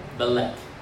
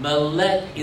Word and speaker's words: melek: And then the last word in this phrase is lek, L-E-C-H. Yeah. Take the letter M and the word lek, put them together and pronounce it for me phonetically. Melek. Yeah. Melek is the Hebrew word for king.